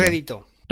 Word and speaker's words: rédito: ¿Cómo se pronuncia reditúo? Reditúo, pues. Estás tratando de sacar ganancia política. – Rédito. – Rédito, claro, rédito, eso.